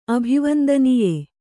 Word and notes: ಅಭಿವಂದನೀಯ: abhivandanīya

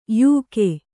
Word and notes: ಯೋಗಿ: yōgi

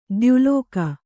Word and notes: ದ್ಯುಲೋಕ: dyu lōka

